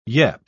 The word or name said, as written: yep